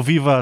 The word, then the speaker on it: Viva: ¿Por qué digo viva así pronunciado? Porque está en portugués. En castellano decimos viva, pero en portugués es Viva Naná.